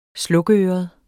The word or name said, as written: slukøret